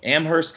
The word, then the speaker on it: Amherst: You mentioned to me that you graduated in 1999 from Amherst College. Did I pronounce that right? – Yes, you did.